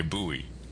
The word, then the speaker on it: Bui: They said B-U-I, Bowie.